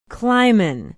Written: clyman

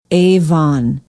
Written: avon

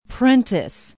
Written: Prentice

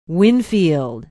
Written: winfield

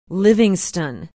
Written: livingston

